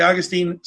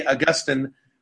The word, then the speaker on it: Augustine: There's disagreement as to how to pronounce Augustine. Some say Augustine, some say Augustine. Chad tends to go back and forth between the two.